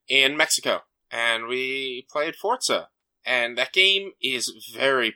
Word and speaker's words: Forza: Am I saying it wrong, or are you saying it wrong? Because it sounds like you're saying it with a T. – It's how it's supposed to be pronounced, Forza.